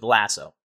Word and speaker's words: Lasso: So the first obvious question, before I get to kind of the normal questions that we ask, is it Lazo or Lasso, or how do you say the difficulty? Because there's people that will take different sides on how to say it, and some people will get upset if you say it incorrectly. And exactly. Just so you don't upset people, you just go with Lasso.